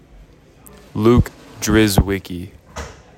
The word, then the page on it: Luke Drzewiecki: LUK driz WICK ee